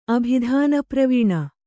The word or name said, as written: ಅಭಿಧಾನಪ್ರವೀಣ